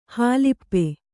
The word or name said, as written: ಹಲಪಿ